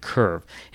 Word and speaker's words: CURV: So they had to bring in an unmanned torpedo recovery vehicle that was called CURVE, and that's spelled C-U-R-V. There's no E at the end, CURVE.